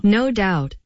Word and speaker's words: No Doubt: Comment on prononce ça? – No doubt. – Ah, comment donc? – No doubt. – OK, merci. Plus de temps. C'est no doubt.